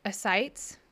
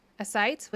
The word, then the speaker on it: ascites: It's like A-S-C-I-T-S. – Hold on. Everyone, hold on. Ascites. Ascites, which is basically like collection of water or something like that.